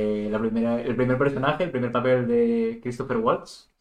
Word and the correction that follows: Christoph Waltz: si lo he dicho bien. Que... Christoph Waltz.